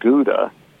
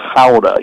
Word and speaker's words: Gouda: If you looked at it, that's where the cheese Gouda is made, and it's not Gouda. In the Netherlands and the Dutch, they call it Gouda. You got to kind of grunt a little bit as you say it. So that's the correct pronunciation of that cheese.